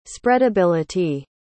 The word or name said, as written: spreadability